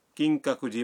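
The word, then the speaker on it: کنگ کاکو جی (金閣寺): کنگ کاکو جی مندر جانا چاہتے ہیں، مندر کے نام کا تلفظ ہے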